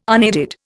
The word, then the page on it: unaided: Indian